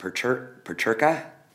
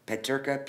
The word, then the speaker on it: Peterka: I think it's pronounced Peterka, Peterka, P-E-T-E-R-K-A. So is that before or after Schmidt, which came out in 2021? – I believe that that is after.